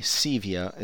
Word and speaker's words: SEVIA: And this letter, I first, right now, there's an organization called SEVIA, SEVIA is how it's pronounced, and that is the Szechuan Electronic Vapor Industry Alliance.